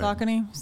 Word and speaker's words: Saucony: Saucony.